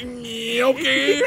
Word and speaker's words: gnocchi: Oh, Courtney, it's pronounced gnocchi.